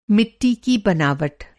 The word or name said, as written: बनावट